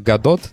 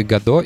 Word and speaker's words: Godot: Итак, первое, что стоит знать о Godot, то, что название этого движка произносится не так, как пишется. Это очень фрустрирует, но об этом я узнал дав- давно, не, три недели назад, потому что пишется он как годот, но Т не читается.